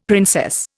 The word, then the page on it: princess: Indian